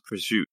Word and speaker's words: prosciutto: But It's spelled prosciutto, but it's pronounced prosciutto.